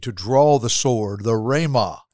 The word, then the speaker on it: rhema: I've I've learned a lot, especially about the point that you brought up there earlier in our podcast about uh, is it rhema um, the best I can tell pronunciation, Rayma. Rayma. Yeah. – Rayma, certainly. So